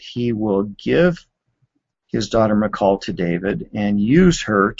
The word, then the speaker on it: Michal: And then we learn that in verse 20, Saul's daughter, Michal, there are several different pronunciations for it, but that's sort of the traditional English version. Saul's daughter, Michal, fell in love with David.